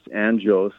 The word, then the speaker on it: Anjos: You got Mario McKinney, Tay Hardy, Jonathan Dos Anjos, which, by the way, I think you'll find it's going to be Anjos because he's from Brazil and they pronounce their J's like that.